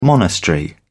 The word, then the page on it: monastery: British English